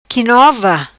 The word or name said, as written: qui nova